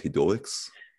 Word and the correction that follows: hedonists: Hedonists. – Hedonists.